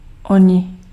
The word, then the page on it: oni: /ˈɔ.ɲɪ/